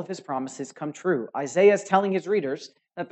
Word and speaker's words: Isaiah: Well, then you need to hear and soak in every word that I almost said Isaiah. That's how the British say it. They sound real cool. Isaiah. But it's Isaiah for us.